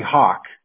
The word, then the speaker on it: Hawk: Yeah, Hawk, it's like the bird, right? The bird. – Right, like the bird, exactly.